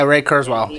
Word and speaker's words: Kurzweil: Yeah, Ray Kurzweil.